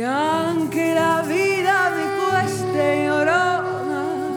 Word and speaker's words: Llorona: Llorona, c'est ça ? – C'est ça. – Llorona.